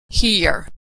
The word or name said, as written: hear